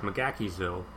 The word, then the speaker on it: McGaheysville: We went down to a uh, Massanutten resort in Virginia, which is like right in McGaheysville, or as I've learned from the people who live there, it's pronounced McGackiesville because the Irish settled in there and the way they pronounce shit.